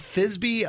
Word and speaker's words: Thisbe: Thisbe? I don't even know if I'm saying that right. – Thisbe? – T-H-I-S-B-E. Thisb? – That's got to be Thisbe, yeah.